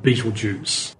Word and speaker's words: Betelgeuse: And what you're looking at there is a star with a spectacular Arabic name, which has been mispronounced so long that we now call it Betelgeuse. It was originally something along the lines of Aptelyaza, meaning shoulder of the giant.